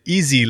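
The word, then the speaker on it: EAISI: It's not maybe the right linguistic pronunciation, but yes, it's the Eindhoven AI Systems Institute. – Right. – We tend to pronounce it as easy.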